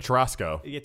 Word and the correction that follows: Terastal: Now that you guys have said it wrong, I can't remember how to say it. – It's the Terastal. Terastal.